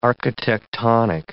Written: architectonic

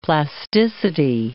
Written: plasticity

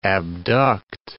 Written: abduct

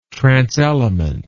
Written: transelement

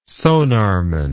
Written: sonarman

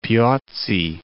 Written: Piozzi

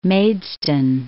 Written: Maidstone

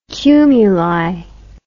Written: cumuli

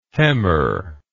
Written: hemmer

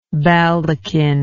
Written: baldachined

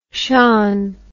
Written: shon

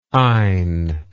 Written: eyne

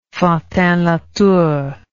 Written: fantinlatour

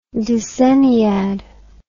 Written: decenniad

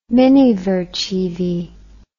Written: minivercheevy

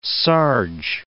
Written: sarge

